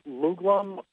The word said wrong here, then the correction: Lugol's: Uh, yeah. Lugol's. Yeah. How do you spell that? – Um, it's L U G O L.